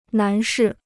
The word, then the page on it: 男士: nán shì